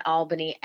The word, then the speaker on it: Albany: Uh, I was born in Albany, Georgia, and the folks in Albany actually pronounce it Albany but I was born in Georgia, raised for the most part in the South.